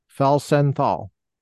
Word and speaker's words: Felsenthal: It's Felsenthal. – Felsenthal. – Yes.